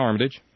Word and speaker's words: Armitage: Is that how you say his name? – Yeah, Armitage.